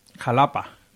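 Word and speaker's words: Xalapa: En Veracruz, en el estado de Veracruz, está Xatapa, me parece. – Jalapa. – Jalapa, se dice con X, sí. – Jalapa, que se escribe con X.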